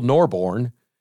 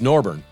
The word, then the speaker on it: Norborne: Or you can Google Norbourne, Missouri, but it's spelled Norbourne. N-O-R-B-O-R-N-E. It's like Tom Osborne. But it's Norbourne.